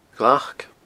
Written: glac

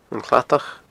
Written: An Cladach

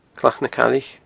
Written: Clach na Caillich

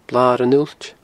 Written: Blar an Uillt

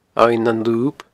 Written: Abhainn nan Lub

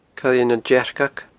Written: coillenandearcag

